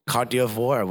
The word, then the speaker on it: Côte d'Ivoire: He saved Côte d'Ivoire with that perm. So how did you say that again? – Côte d'Ivoire? – No, that's not how you say it.